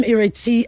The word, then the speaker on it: Ireti: I'm Ireti. I am your host, pronounced with an E, spelt with an I.